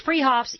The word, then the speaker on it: Fruhoffs: Spelled Fruhoffs, pronounced Freehoffs.